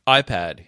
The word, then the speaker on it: iPad: Usually the stress is on the first syllable. – Is it? – iPad, yeah.